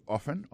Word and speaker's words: often: Oftentimes nan, c'est O-F-T-E-N, times, T-I-M-E-S. Alors, c'est kon sa ou di sa. So, ou di often. Often nan, c'est O-F-T-E-N, malgré l'écrit often, yon prononce often, alors m'kwe que T a bébé.